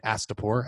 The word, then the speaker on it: Astapor: which is in Astapor.